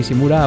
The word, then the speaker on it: Ishimura: Y ahora, amiguis, heraldos del terror, da igual que sea en los oscuros pasillos del USG Simura, ahora sí lo he dicho bien, o en vuestra casa por la noche, con ruido o con silencio, en cualquier parte, pero siempre con música.